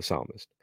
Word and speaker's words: psalmist: It's like, forget that the P is silent and all that. So the psalmist.